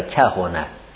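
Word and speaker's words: کهانة: این کهانه عین الفعلش ذمه داره، کهانه، کهانه. این موقع که ذمه شما موقع تلفظ کنی لب دو غنچه میشه، بسته میشه، کهو، متوجه هستی؟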